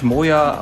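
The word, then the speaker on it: Máire: Und das ist, ja, Marir Brennan, ich glaube, es wurde gälisch Moya ausgesprochen und dann hat sie den Namen irgendwann mal auch komplett auf Moya geändert.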